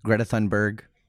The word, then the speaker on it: Greta Thunberg: Greta Thunberg. – Tell us more. – I think I got her name right. – That sounds right. – Greta Thunberg.